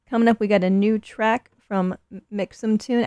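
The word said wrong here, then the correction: mxmtoon: Actually, I think I just read that it's MXM Tune is the pronunciation, and I've been doing it wrong this whole time.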